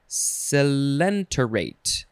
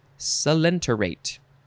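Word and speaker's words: coelenterate: It is spelled C-N-I-D-A-R-I-A-N, cnidarian. Noun from circa 1909, and the synonym is selenterate. Now, do I need to go back and figure? No, I'm not going to do that. It is spelled C-O-E-L-E-N-T-E-R-A-T-E. I think it's selenterate.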